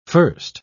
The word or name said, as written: first